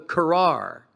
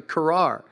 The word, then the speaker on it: karar: It's called the karar. Like put an extra R in car and you pronounce it right, the karar.